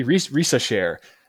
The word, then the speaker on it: Risacher: But the 6'9 French wing, Zachary Richler, Yeah. Zachary Risacher. – Risacher.